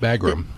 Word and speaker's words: Bagram: The idea that they closed the Bahrain air base? I'm not sure I'm saying that correctly. – Oh, Bagram, yes.